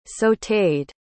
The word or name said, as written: sauted